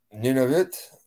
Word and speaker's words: Nunavut: Is that how you pronounce that? – Nunavut? – Yeah, that's one of the Nunavut.